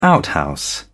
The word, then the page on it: outhouse: British English